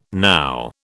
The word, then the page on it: now: British English